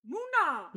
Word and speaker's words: Muna: Muna! – Yes, Muna. You pronounced it correctly.